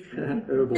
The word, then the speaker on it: herbal: Basil is an herbal, and I can say herbal, you'll understand that in America they don't pronounce the H, herbal, antibiotic and antiseptic, carminative and appetizer that has a special affinity for the stomach.